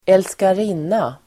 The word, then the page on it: älskarinna: [²elskar'in:a]